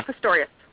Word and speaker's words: Pistorius: Pistorius.